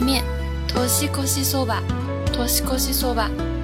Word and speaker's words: そば: 日本人喜欢吃的这个荞麦面啊，就读作そば，そば，它和我们经常听到的一句歌词そばにいるよ，そばにいるよ。我会永远在你身边哦，发音呢是完全一样的，所以注意了，这里的 soba 表示的是荞麦面哦。